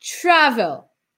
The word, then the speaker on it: travel: A gente tem o CVC no final, mas a minha sílaba mais forte é o tra. Não é o travel, é travel. Travel.